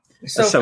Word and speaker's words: Ahsoka: And then he said next spring, Ahsoka, is that how you say her name? Ahsoka? – Ahsoka.